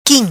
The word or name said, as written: Quim